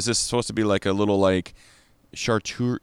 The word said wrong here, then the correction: charcuterie: charcuterie board? How do you say that? – Charcuterie. Charcuterie. Charcuterie.